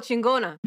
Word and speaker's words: chingona: And then she goes for the a knee to the face, but she ends up getting is it Shin Ganga, or how is that pronounced? Masking Rose? Yeah. – I'm not... Oh, Chingona.